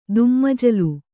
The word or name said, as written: ದುಮ್ಮಜಲು